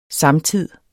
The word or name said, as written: samtid